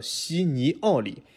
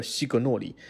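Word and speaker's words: Signori: 刚才我们说这个断音的地方又断错了，就是 G N 其实一起的是叫尼，所以它的正确发音应该叫西尼奥里啊，不叫西格诺里，也没有这个格的音。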